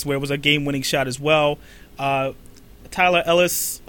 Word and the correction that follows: Tyler Ulis: probably saying his name wrong. Tyler Ulis.